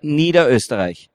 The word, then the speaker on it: Niederösterreich: Niederösterreich.